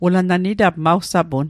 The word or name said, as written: sapun